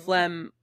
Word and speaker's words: phlegm: It's like P H L E G M.